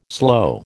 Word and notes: slow: American English

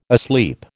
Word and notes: asleep: American English

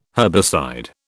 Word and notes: herbicide: British English

